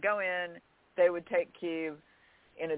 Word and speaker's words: Kyiv: And also want to point out that for our American listeners, there's Kyiv and then there's Kiev. And Kiev is the Russian pronunciation. Kyiv is the Ukrainian capital. So don't call Kyiv Kiev. That is not how it is said.